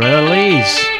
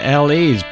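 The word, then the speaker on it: La's: Now, Neil Mavers, or Mavers, whichever, I think Mavers you pronounce it, he played with the Lars, or LAs. I prefer the LAs, but I think it's the Lars officially.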